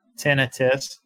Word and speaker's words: tinnitus: How is it pronounced? – It's tinnitus. – So tinnitus. Yeah.